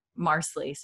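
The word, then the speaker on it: Marsali: Marsley, like parsley is how they say to say her name.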